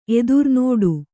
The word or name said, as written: ಎದುರ್ನೋಡು